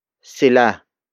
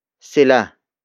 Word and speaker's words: sila: The next word is sila. Sila. Sila. Sila is spelled as S-I-L-A.